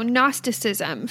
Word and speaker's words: Gnosticism: Okay, so Gnosticism, first of all, it is spelled G-N-O-S-T-I-C. That's Gnostic. So it has a G at the beginning that is silent.